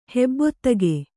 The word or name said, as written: ಹೆಬ್ಬೊತ್ತಿಗೆ